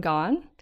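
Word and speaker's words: Gawne: So so this episode we had a chance to interview the Lingthusiasm hosts, Gretchen McCulloch and Lauren Gaughan, which we all pronounce incorrectly because we don't have the correct vowel. – Yeah, but she's nice and says that it's okay to pronounce it like gone as a gone girl.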